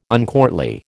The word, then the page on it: uncourtly: American English